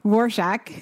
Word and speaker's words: Rorschach: Rorschach. Right? – Yeah. Rorschach. Saying, right? Yeah. Rorschach. – Okay. Because it's not spelled the way it's pronounced at all.